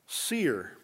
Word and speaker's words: Seir: Now that word hairy is the Hebrew word seer, pronounced or spelled S-E-I-R.